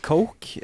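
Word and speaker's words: Koch: Ja, jeg vet det uttales Coke, eh, men jeg kommer til å fortsette å si Erik Kokk, for det er mye morsommere.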